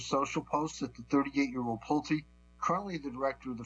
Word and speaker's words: Pulte: The announcement comes amid furious backlash toward interim DNI Bill Pulte, I believe is how it's pronounced, P-U-L-T-E, who lacks any intelligence experience and comes from the housing sector.